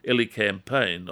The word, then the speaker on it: elecampane: It again is a European herb called elecampane. I'll spell it for you, E-L-E-C-A-M-P-A-N-E, elecampane.